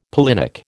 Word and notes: pollinic: American English

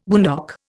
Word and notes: boondock: Indian

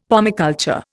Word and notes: pomiculture: Indian

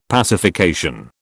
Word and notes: pacification: British English

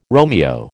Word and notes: Romeo: American English

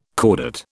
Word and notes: chordate: British English

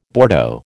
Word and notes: Bordeaux: American English

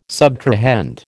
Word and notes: subtrahend: American English

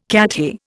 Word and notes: Cathay: Indian

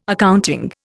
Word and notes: accounting: Indian

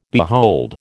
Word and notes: behold: American English

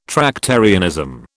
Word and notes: Tractarianism: British English

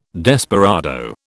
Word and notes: desperado: British English

